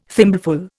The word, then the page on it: thimbleful: Indian